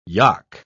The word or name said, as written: yock